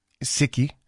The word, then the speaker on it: Sikhi: Because through COVID, I know that some of the biggest tasks that the Sikh faith and the Sikhi have taken on, and if I mispronounced that context, please let me know. Sikhi. Thank you. – Yeah, very close.